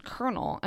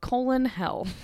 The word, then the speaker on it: colonel: I'm 29, and I only recently did I learn that Colonel is pronounced kernel and not colon hell. So this is obviously the military spelling of colonel. C-O-L-O-N-E-L.